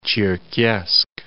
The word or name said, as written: Cherkessk